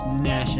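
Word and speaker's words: gnash: It's Nash and not Ganash. – Yeah. – It's spelled Ganash. – The G is but silente.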